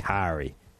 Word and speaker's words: Kaare: K-A-A-R-E, which I'm going to say is pronounced Kari.